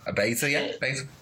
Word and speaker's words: Baszler: how do you say her name? Baszler? – Baszler.